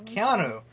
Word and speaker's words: Keanu: Keanu.